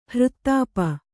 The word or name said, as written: ಹೃತ್ತಾಪ